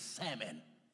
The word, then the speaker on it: salmon: Just like the L and salmon. And salmon is silent. It's salmon. Throw that out there.